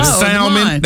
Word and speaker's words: salmon: Salmon. That's salmon. – Salmon has that, well, that L is supposed to be silent, ain't it? – Kind of, sort of.